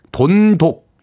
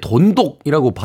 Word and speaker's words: 돈독: 돈독. 이렇게 이제 읽게 되는데 누구랑 돈독해지고 싶다 할 때는 이제 돈독이라고 발음을 하고 돈독이 올랐다 할 때는 돈독이라고 또 발음을 하게 됩니다.